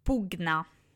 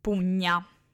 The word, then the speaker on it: pugna: Altra particolarità è il suono GN, che appunto veniva pronunciato con la G dura, quindi con la G, con un suono GN ad esempio pugna, regnum, agnus e non come in italiano, come la pronuncia tradizionale, pugna, agnus, regnum.